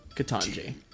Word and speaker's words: Ketanji: How do you say it? Ketanji. – Ketanji. – Okay. – Like Jumanji. – Ketanji like Jumanji. – Ketanji like Jumanji. I love that.